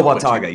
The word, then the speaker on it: Watauga: Oh, Watauga.